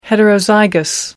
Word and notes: heterozygous: American English

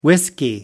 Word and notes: whiskey: British English

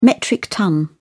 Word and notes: metric ton: British English

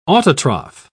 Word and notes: autotroph: British English

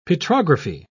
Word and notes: petrography: British English

